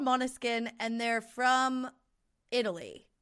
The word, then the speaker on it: Måneskin: And they're called Måneskin, which is, it's spelled M A with like the little circle over it. N E S K I N. But it's Måneskin is how you like say it.